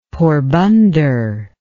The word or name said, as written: Porbandar